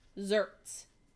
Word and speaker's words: xertz: Zerts. X-E-R-T-Z.